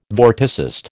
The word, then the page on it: vorticist: American English